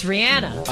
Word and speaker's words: Rihanna: Oh, by the way, speaking of musicians, we've been mispronouncing Rihanna's name for the longest time. – I know. – It's Rihanna.